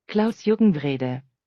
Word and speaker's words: Klaus-Jürgen Wrede: This is the correct pronunciation of the designer's name. Klaus-Jürgen Wrede. Congratulations, Klaus.